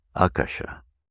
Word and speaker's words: Akasha: And in the English, we tend to want to say Akasha, Akasha, Akasha, and then Akashic but actually we put the accent on the A, the first A. Akasha.